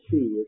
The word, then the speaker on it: Hsi: The man was, you may have read about him, Pastor Xi, it's spelled HSI.